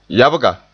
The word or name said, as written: yabga